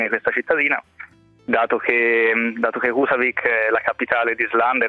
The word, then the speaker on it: Húsavík: Husavik. – Husavik, certo, ok.